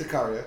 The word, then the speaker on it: Sicario: Yeah, that's Sicario.